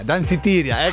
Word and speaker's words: Danceteria: Wow, si chiama Danceteria, ok? È così la pronuncia? Danceteria, eccolo lì.